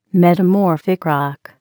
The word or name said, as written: metamorphic rock